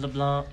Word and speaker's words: LeBlanc: And then LeBlanc. No C, silent C. – LeBlanc.